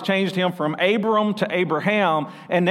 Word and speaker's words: Abraham: So God took Abram and made him Abraham, and there's emphasis on the H there, and I could go on a rabbit trail there, but I'm not.